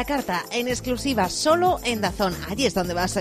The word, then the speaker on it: DAZN: Pero a estas alturas ya sabes que se pronuncia pronuncia DAZN.